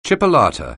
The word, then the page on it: chipolata: anglais